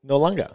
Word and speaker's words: Noarlunga: Port Noarlunga. – That's a very good pronunciation.